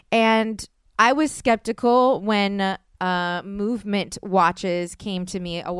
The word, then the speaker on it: MVMT: So let me explain, because it's MVMT watches, but it's pronounced movement watches.